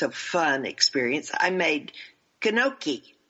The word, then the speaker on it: gnocchi: Did I say that right? Gnocchi. The G is silent, right? Gnocchi. – Yeah, the G is silent. Yeah. Gnocchi is how they say it. – Oh. – You're doing pretty good, though. You knew the G was silent.